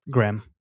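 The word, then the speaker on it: Graeme: I think that's how you say his name. It's spelled kind of weird. G-A-G-R-A-E-M-E. – Graham. – Yeah, Graham Cameron.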